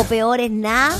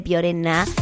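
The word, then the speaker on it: Peor es Nada: Como por ejemplo, ese lugar que se llama Piorená o Peorená. En el chileno se dice Piorená.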